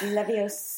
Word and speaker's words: Leviosa: It's Leviosa. – It's Wingardium Leviosa, right? I said it wrong the first time.